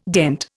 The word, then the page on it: dent: Indian